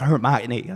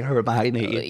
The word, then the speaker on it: Hermione: It's like Hermione. Like no one knew how to say Hermione. – Yeah, everyone thought it was like Hermione.